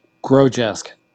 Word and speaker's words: Grodjesk: How do you pronounce your last name? – Grodjesk.